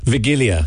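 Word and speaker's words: Wigilia: Vigilia is the correct pronunciation, which I think comes from vigil. – Okay, yeah, because so W is pronounced V. – Yeah, in Polish, and V is pronounced W.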